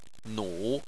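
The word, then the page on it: nu: tone 3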